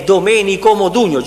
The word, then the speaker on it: Domenico Modugno: È Domenico Modugno, giuro.